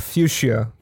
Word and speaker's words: Fuchsia: And I don't know how to actually pronounce that Fuchsia. I don't know. – It should be called Fuchsia, from what I've heard.